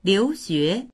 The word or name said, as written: liu2xue2